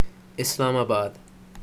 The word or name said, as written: Islamabad